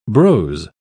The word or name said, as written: brose